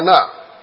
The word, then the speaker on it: anah: Now the word to humble you there, which is where you have afflict, and suffered you to hunger, is the word ana, A-N-A-H.